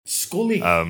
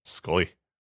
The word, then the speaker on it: Sxollie: But I'm recommending Scholly Cider, which is spelt S-X-O-L-L-I-E. – An odd name. – And it's it's South African, I think. I think that's possibly a way of doing a K sound. – Scully. – Scully. Grams. – Grams. Can you pass me a bottle of delicious Scully?